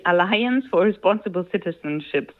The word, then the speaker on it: Alliance for Responsible Citizenship: Das ist die Konferenz Alliance for Responsible Citizenship, so spricht man das aus.